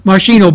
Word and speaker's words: Moschino: M-O-S-C-H-I-N-O, Moschino Barbie.